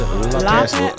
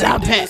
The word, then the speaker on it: LaPat: Is that how you pronounce it? – LaPat. – LaPat. – It sounds real. It's French. LaPat.